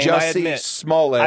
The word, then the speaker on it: Jussie Smollett: Jussie Smollett.